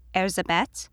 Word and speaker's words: Erzsébet: As a Hungarian noblewoman, her given name would have been pronounced Acheti Bathory Erzsébet, but I will be referring to her as she is most commonly renowned today for the rest of our story.